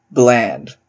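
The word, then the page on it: bland: Canadian English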